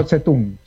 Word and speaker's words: Zedong: La D se pronuncia como T. – Zedong. – La Z como C. Ajá. Mao Zedong sería.